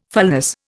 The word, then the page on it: fulness: Indian